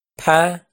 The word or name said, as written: phab